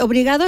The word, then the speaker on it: obrigado: ¿Cómo, obligado, sí, ¿cómo se dice? Obrigado es gracia, ¿no? – Obrigado, gracias. – Sí, obrigado. Hoy voy a tener que poder aprender portugués para hablar contigo.